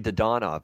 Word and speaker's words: Dadonov: Is that how you say his name? His name is so weird to me. I hate saying it. – That is how it is now. Weird. It's actually Dadonov now, I believe. – Dadonov. Okay. – Yeah. Dadonov. I asked for a pronunciation change, apparently, before the season.